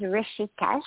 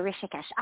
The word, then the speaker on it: Rishikesh: Rishikesh. Yeah, it's three syllables, Rishikesh. – Okay. – Yeah, Rishikesh. R-I-S-H-I-K-E-S-H.